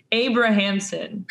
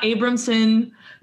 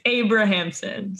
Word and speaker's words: Abrahamson: It's Abrahamson. So it's like you pronounce all the syllables. It's not Abramson.